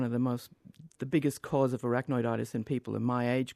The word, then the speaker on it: arachnoiditis: Well, you have arachnoiditis. Did I say that right? – Yes, correct.